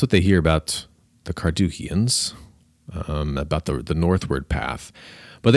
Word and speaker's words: Carduchians: And a lot of modern scholars think that these Carduchians, listen to that first syllable, Carduchians, a lot of modern scholars think that these are the ancestors of the Kurds.